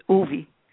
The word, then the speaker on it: Uvi: It's actually pronounced Uvi, just like Uvi.